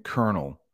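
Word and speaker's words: colonel: Modags is talking about kernel and kernel. They are pronounced the same way.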